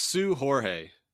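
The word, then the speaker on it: Seu Jorge: Whether you take the Is it George Seus? I can't pronounce his name. You're Portuguese. – It's Sue Jorge. – You're not a Sue Jorge. Okay, got it.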